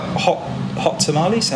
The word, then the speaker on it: Hot Tamales: So what's this is like hot tamales?